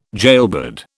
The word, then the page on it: jailbird: British English